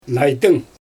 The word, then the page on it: najdyng: Xyzyl